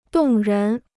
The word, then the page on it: 动人: dòng rén